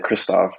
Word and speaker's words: Krzysztof: I say easier because it's spelled more like it sounds, unlike my first name, Krzysztof, which, you know, it's the hidden sound name. Like it has two Z's in it.